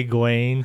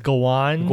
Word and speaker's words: Gawain: We discovered that that's not how you say Gawain. – How you say it? – It's Gawain.